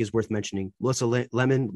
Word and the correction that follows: Lehman: Melissa Lehman. I apologize for not uh, knowing how to say her last name, but the woman's Le- coach, Lehman, Lehman, coach Lehman. Thank you.